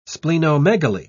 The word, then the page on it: splenomegaly: British English